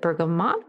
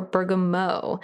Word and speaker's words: bergamot: Is it bergamot or bergamot? So you spell it BERGAMOT. And I've heard it pronounced both ways. So I kind of will use both interchangeably, just depending on how I feel like saying it.